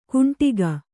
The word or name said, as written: ಕುಂಟಿಗ